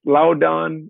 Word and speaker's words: Loudoun: It's Loudoun United FC. It's coming. – You did it right. – You know, he's getting better because you know, there's Loudoun, you know, that Loudoun.